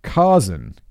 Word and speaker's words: Kazan: Kazan, there's a pronunciation guide. Aaliyah, Kazan, Kazan, like Steve Zahn.